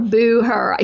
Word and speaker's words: Booher: And my name is spelled with two N's, D-I-A-N-N-A dot Booher, B-O-O-H-E-R. A lot of times people misspell Booher. It's just like, she's not a talker, she's a doer. That's how you pronounce Booher. But B-O-O-H-E-R, Booher, except I hope they don't.